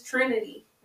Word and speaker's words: Trinity: My name is Trinity. Rhymes with Renity.